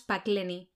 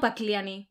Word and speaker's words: Pakleni: Sí, y luego un grupo de islotes que son las Pakleni. – Sí. – Yo las llamo Pakliani. – Pakleni, se escriben con E. Pakleni.